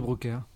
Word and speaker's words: Brouckère: Volvemos a Django, Place de Brouckère, ¿cómo lo pronunciamos? ¿Cómo se pronuncia? – Fred de Brouckère. – Mejor, mucho mejor.